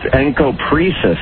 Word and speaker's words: encopresis: You need to pronounce the name correctly. – Did I get that wrong? How do you pronounce that? – It's Encopresis. – Encopresis.